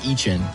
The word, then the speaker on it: Etienne: Before, there are the likes of Travis Etienne right before him. – Travis Etienne. – Wow. – Etienne. – Why'd you do that? – I actually think it's Etienne. Didn't he say that? – He was trying to pronounce it.